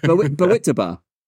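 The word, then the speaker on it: Bawitdaba: Well, how do you pronounce it? Bowie Dabba? – Bawitaba. – Bawitaba. – What is it? It is Bawitaba.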